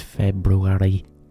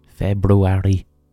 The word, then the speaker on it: February: It's February. February. I never know how to pronounce it. February. February. It's February. February. February. No one's pronouncing that R. I get very pissed off with the R in February.